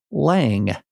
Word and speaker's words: Lange: We have done an instant oral argument transcript fact check, or oral argument a recording fact check, and it is Lange. – That doesn't actually mean it's Lange, but it means that we're going to say it Lange. As we know, the court has actually often mispronounced party names as well. And so these things end up being ambiguous through time, but we'll go with Lange as the chief did.